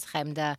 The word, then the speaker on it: Chemda: My name is Chemda. It's spelled C-H-E-M-D-A.